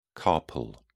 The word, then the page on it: carpal: British English